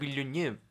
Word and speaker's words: Villeneuve: Y hoy vamos a estar hablando sobre Arrival, la nueva película de Denis Villeneuve. Así es. – Qué bien pronunciado ese apellido. – Villeneuve.